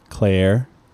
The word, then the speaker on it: Cleyer: But this guy, Andres Kleier, or Clura, how would you pronounce his last name? Can you see it? It's spelled C-L-E-Y-E-R. – Claire.